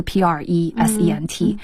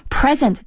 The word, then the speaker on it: present: 当然另外的话比如说一个英语的单词，比如说 present 是一个礼物拼写的话是 present， present 这个词如果读成 present 就变成展示，对，或是 you know presently 现在的这个，所以一个词它的读音不一样的话，其实这整个意思都不一样了，对。